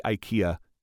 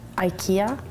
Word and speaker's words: IKEA: Here's a little clip from the video on how apparently they say IKEA in Sweden. – In Sweden, the store IKEA.